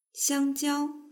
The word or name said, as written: xiangjiao